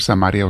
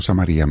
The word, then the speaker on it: Samaria: Se puede pronunciar Samaria o Samaria, mis hermanos, de las dos maneras.